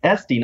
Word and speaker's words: Estee: One of the most important things to know about this business, Vince, is that it was founded by Estee, pronounced Estee, not Estee, Estee and Joseph Lauder in 1946.